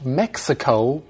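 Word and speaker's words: México: Well, actually, in Spanish, Mexico is not pronounced with a hard X sound. Mm. It's Mexico with a more of an H sound.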